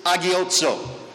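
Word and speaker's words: agiotso (ἁγιάζω): In the New Testament, the terms that we are using is from the root word hag, H-A-G, hag. We would pronounce this agiotso, to sanctify.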